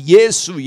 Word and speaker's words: Yesu: He was calling out, Yasso, Yasso, because he was not able to pronounce the name of Jesus in Korean properly, which is Yesu, Yesu.